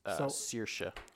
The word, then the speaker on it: Saoirse: It's pronounced. Saoirse.